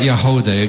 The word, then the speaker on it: Yagody: If you want to know more about Yahodeh, again, it's spelled Y-A-G-O-D-Y, all you have to do is go to the Woodsongs website, click on the archive page.